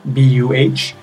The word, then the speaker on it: Buh: Uh, you can find me on Twitter at Gary Buh, G A R Y B U H, um, pronounced Gary Buh.